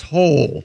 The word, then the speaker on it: Hole: But it's pronounced Hole. – Yeah. – Ole? – Detective A. Hole. Yeah. – It's spelled H-O-L-E. Detective Hole.